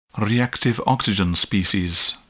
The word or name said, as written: reactive oxygen species